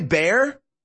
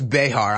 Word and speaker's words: Behar: Is it Joy Bear? Yeah, I know it's Behar. I'm pronouncing her name wrong. Intentionally, I'm going to say it wrong every time.